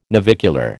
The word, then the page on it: navicular: American English